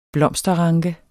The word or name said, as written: blomsterranke